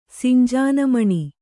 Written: ಸಿಂಜಾನಮಣಿ

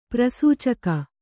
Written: ಪ್ರಸೂಚಕ

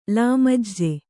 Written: ಲಾಮಜ್ಜೆ

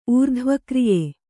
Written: ಊರ್ಧ್ವಕ್ರಿಯೆ

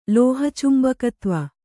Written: ಲೋಹಚುಂಬಕತ್ವ

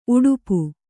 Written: ಉಡುಪ

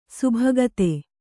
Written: ಸಬ್ಬಗತ

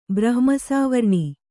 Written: ಬ್ರಹ್ಮಸಾವರ್ಣಿ

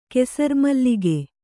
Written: ಕೆಸರ್ಮಲ್ಲಿಗೆ

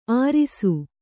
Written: ಆರಿಸು

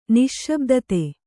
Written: ನಿಶ್ಶಬ್ದತೆ

